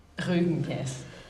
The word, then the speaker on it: Rügen: Whenever I come to Rügen, how is it pronounced, Rügen? – Rügen. – Rügen. – Rügen, yes.